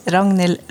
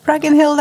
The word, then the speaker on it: Ragnhild: Ragnhild, that's great. Because if you just do the little G in the middle there, you know, it's doable in English.